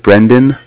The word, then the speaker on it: Brendan: Yeah, my name is Brendan. That's spelled B-R. Yeah, spelled B-R-E.